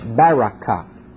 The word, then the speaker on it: Berechah: B-E-R-E-C-H-A-H, Barakah. Barakah, wherever you want to put the syllable.